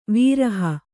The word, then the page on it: ವಿರಹ: viraha